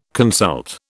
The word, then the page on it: consult: British English